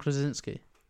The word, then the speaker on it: Krasinski: How do you say it? – Not John Travolta. John Krasinski. Krasinski. – Krasinski. It's like saying Mike Wazowski.